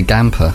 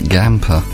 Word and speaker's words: Gamper: Yeah, I say Gamper. It's like Stamper, but Gamper.